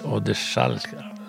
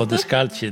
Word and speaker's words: Odescalchi: Och sen gick den över till någon som heter Odeschalka. Inte hur det uttalas. Odescalchi. Kan du italienska? – Nej, jag chansar. Odescalchi, det tror jag stämmer.